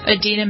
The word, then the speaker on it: Idina: Idina Menzel.